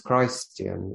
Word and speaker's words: Christian: It would be presumptuous of me to say I'm Christian, because Christian, yep, change the pronunciation, it's Christian, which is Christ-like.